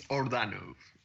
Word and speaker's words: Ordano: Iordano, is that correct, Esteban? – It's Ordano. – Ordano, all right.